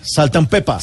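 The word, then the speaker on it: Salt-N-Pepa: Ah, bueno, también es del slang, Saltan Pepa. Saltan Pepa. Sí, se escribe con, mm. N intermedia, pero no dice ant, sino ah, Saltan Pepa.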